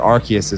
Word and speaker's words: Arceus: I've always said Arceus, but I have slipped with Arceus, and I think Arceus is the official way to pronounce it.